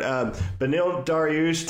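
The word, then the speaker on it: Beneil Dariush: Benil Dariush taking on Jakar Close. Do you like that pronunciation? Was that pretty good? – Yeah. Yeah, it's perfect. He's also American, so I'd hope you could get that guy's name right.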